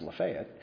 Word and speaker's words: Lafayette: Oxford is, and Lafayette County, which is spelled Lafayette, but it's pronounced Lafayette, we're in the second healthiest county.